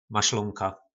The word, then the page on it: maslonka: po nasimu